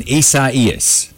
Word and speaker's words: Isaias: Hurricane Isaias. That's how you pronounce it. Because I looked it on Facebook, and there was a guy. This is how it's pronounced. It's a Spanish word, Isaias.